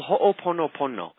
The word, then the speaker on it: Ho'oponopono: It's called Ho'oponopono.